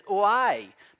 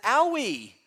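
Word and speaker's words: oi (οὐαί): The Greek word here is oi, but I like to pronounce it owie. Woe. Woe to you. That would be mispronunciation, but oi reflects.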